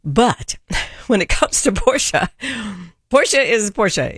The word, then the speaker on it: Porsche: So the company I work for is Porsche, and the reason that the name is pronounced that way is a little bit of a story.